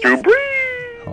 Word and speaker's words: Drew Brees: Oh, how you say it? Drew Brees? – Drew Brees.